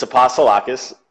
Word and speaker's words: Apostolakis: It's Apostolakis.